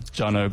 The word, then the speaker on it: Jonex: I didn't do myself any favors with the spelling of my name, but it's Jonex, and yeah, you just pronounce it Jono.